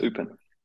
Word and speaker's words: Eupen: But the last question I'll ask you is, you know, you're not with am I pronouncing it right, KAS Eupen or KAS Eupen? I was in Eupen. Yeah, KAS Eupen.